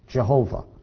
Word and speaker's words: Jehovah: There would be the tetragrammaton, the all caps of the Yod, He, Vav, He, which in English is pronounced Jehovah. How do I know that? Because I have an English Bible, and it's written Jehovah four times in my English Bible. That's how I know that. It's not Yahweh.